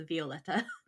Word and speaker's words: Violeta: My apologies for saying Violeta. I should have thought about the fact that it was probably pronounced Violeta.